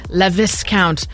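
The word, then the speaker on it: Laviscount: Well, first it was Lucien Leviscount. Did I say that wrong? – That's fine. Yeah, the guy from Emily in Paris. Handsome devil. – It was fine. Okay.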